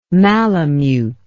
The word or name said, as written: malemute